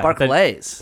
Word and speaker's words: Barclays: I couldn't tell if you were saying Barclays or Barclays. That's why. – Barclays. – Barclays. – Okay. All right. Well. – I forgot to tell you. – East Coast pronunciation.